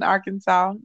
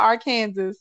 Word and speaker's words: Arkansas: We're cool down here in Arkansas, not Arkansas.